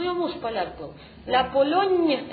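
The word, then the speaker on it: Pologne: Она напоминает вам, что там читается мягкое «ни». Помните в этом буквосочетании? «Л-М» – «Литали», «Ля Полонне» и «Лирлянда».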